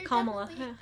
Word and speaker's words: Kamala: I feel like they're Kamala.